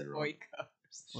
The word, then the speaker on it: Oikos: how do you say it? Oikos? – Okios. – Okios. – I don't know. – It's Oikos.